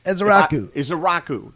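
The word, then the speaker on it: Ezeiruaku: That edge rusher that they're coming up with is Donovan Isiruku. – Isiruku. – There you go. Thank you.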